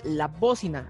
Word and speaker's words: La Bócina: Nos encuentras en redes sociales como La Bocina, acento en la O en todas las plataformas. Y en Instagram como La Bocina Crew, C-R-E-W.